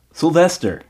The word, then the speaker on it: Sylvester: 예, silver star이 아니고 발음이 원어민 쌤의 버전으로 들어보면 Sylvester. 완전 다르죠.